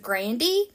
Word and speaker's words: Grande: And that would be Ariana Grande. She did come out a few years ago and say that her last name is actually pronounced Grandy, but no one calls her that, so for all intents and purposes, I will be calling her Ariana Grande.